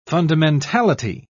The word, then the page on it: fundamentality: British English